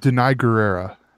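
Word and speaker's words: Danai Gurira: Between Angela Bassett, obviously Denaria, I always pronounce her name wrong, and I should get it right because I call her Michonne, but yeah. I do it, you know. – It's Denai Guerrera.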